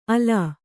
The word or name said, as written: ಆಲ್